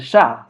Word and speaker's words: Shah: But yeah, people used to call me Mr. Sha. Sha in South America, it's a T, like Sha. They call it a T. And my last name is pronounced as Sha.